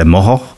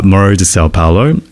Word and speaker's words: Morro de Sao Paulo: Now, this little island's called Morro de Sao Paulo, and I'm saying it so that not like the locals would say Moho, Morro de Sao Paulo, and not Sao Paulo the city, but it's spelt the same way.